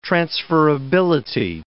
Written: transferability

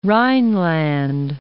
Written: Rhineland

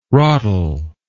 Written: rotl

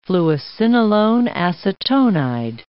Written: fluocinolone acetonide

